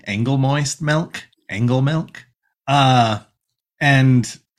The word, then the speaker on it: Angolmois: Princess Moon is like, no, it's pronounced Angle moist. Uh and I'm like, yes, that is how it's pronounced.